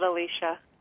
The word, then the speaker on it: Alicia: Well, it's spelled Alicia, but you can call it. We all pronounce it Alicia.